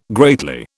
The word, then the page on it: greatly: British English